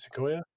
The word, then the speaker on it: Sequoia: So, I mean, what was your guys like, cause there's the Techstars of the world and you know, the, Sequoia, why can't I think of the name? Am I saying that right? Sequoia? – Yeah.